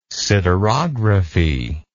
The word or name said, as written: siderography